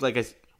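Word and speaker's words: Ljajić: And then they have other notable names in there like Dusan Tadic and Adam Ljajic, if I'm saying that correctly. I think Ljajic. Okay, perfect.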